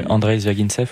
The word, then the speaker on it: Zvyagintsev: Euh, Andrei Zvyagintsev.